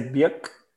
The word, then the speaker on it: Björk: I think actually we're meant to say Bjork. You kind of just pronounce it in a really low key way.